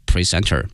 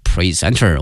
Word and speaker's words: present: 有的时候呢，我们说对某个戏剧啊非常的喜爱，也可以称这个人是一个票友。Was the film's award presenter 是这个电影的颁奖主持人，后面用的这个词叫 presenter。我们学过这个词 present present 两个发音，如果是 present 的话呢，它的词性呢是名词，表示呢礼物，但是呢如果发音发生了变化，如果念成 present 表示说能够展示出来，给予某人某一个东西，那么 presenter 后面加个 er 呢，表示说呢颁奖的这么一个嘉宾或主持人。